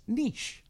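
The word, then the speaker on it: Nych: No, I looked it up on the internet, and it says pronounced Niche. So it's N-Y-C-H is the way they spell it. – Wise. – But it's pronounced Niche.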